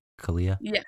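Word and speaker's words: Kealia: Well, people used to be mad at the way Kalia Watt, is that how you say it? Kalia? – Yeah. Pretty sure.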